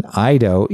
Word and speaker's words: eido: One is, I'm going to just do the English pronunciation as it's written, Eido, E-I-D-O, which is